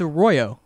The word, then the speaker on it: Arroyo: Rolling R? It's Arroyo. – Arroyo. – It's just an Arroyo. R. It's how the letter R works.